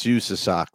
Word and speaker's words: USASOC: That's USASOC. That's how you pronounce that.